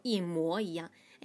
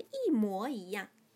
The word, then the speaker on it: 一模一样: 一模一样，最正规的是一模一样，但是大家都不认为模这个字在这个词里面应该念成模，大家都会说一模一样。哎呀，你跟你爸爸长得真是一模一样，大家都会说一模一样，但是最正规的是一模一样。